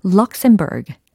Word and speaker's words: Luxembourg: 영어 발음은 과연 어떻게 될까? 먼저 철자 알려드릴게요. L, U, X, E, M, B, O-U-R-G라는 철자입니다. 네. 여러 가지 답안이 들리는 것 같은데요. 바로 이거였죠. Luxembourg, Luxembourg, Luxembourg. 이거였습니다.